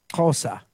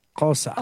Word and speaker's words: Xhosa: The language is Xhosa. That's how they say it. Xhosa. – Oh, isn't that tricky to say? Xhosa.